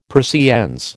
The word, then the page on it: Persiennes: American English